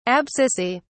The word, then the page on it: abscissae: British English